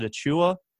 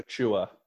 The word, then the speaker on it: Achiuwa: Uh, the the big that took over in Memphis, Precious is it Achiuwa? Is how you say his name? – Yes, Precious uh, Achiuwa.